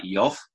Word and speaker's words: Job: You might struggle to work out whether you pronounce it Job or Job. The actual pronunciation is probably more like Yov, because J is Y in Hebrew pronunciation, and V is more like a V. So Yov, if you like.